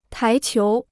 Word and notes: 台球: tái qiú

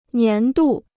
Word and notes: 年度: nián dù

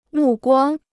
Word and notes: 目光: mù guāng

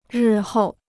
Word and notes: 日后: rì hòu

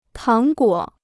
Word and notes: 糖果: táng guǒ

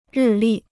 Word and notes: 日历: rì lì

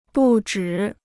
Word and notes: 不止: bù zhǐ

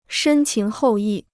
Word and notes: 深情厚谊: shēn qíng hòu yì

